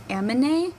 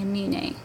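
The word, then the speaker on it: Aminé: It's I pronounced it Amine, but it's actually Amine.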